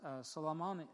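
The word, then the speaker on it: Salmone: We sailed under Crete over against Salamine, and hardly passing, it came unto a place which is called the Fair Havens, nigh whereunto was the city of Lycia. Now, Salamine is how it's pronounced, is the cape just to the east side of the tip of Crete.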